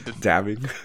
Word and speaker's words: dabbing: All right, I will consider it after I do the dapping video. Did I say that right? – Dabbing. – Dabbing. There we go.